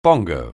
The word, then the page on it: bongo: anglais